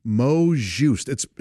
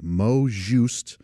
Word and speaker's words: mot juste: Mot juste. It's spelled M-O-T, and then the next word is J-U-S-T-E. Mot juste.